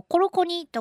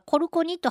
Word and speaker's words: コロコニ: えー、アイヌ語で、えっと、吹きはそのそもそもコロコニとかコルコニと発音するんだそうです。